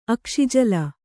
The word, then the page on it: ಅಕ್ಷಿಜಲ: kannada